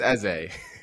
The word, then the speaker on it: Eze: Is it pronounced Eze? Like, is that the right pronunciation? You are muted, sir. I can see you talking. – I believe it's Eze. – Eze. Okay, Eze.